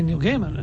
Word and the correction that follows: Gaiman: and then somebody said Gaiman one day, and I thought, oh, God, I've been saying it wrong for years.